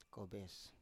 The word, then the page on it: kobes: Biak